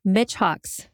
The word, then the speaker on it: Michaux: Michaux State Forest, M-I-C-H-A-U-X.